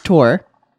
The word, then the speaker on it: Thor: I'm sick of people saying Thor. It's called Tor. It's pronounced Tor.